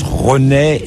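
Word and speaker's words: Ronse: On dit, ça s'écrit Ronex en flamand, ça se prononce Ronais et en français c'est Ronce.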